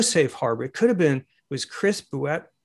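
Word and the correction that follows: Boutet: how do you pronounce it? – Bouté, it's French. – It's Chris Bouté.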